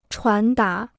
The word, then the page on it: 传达: chuán dá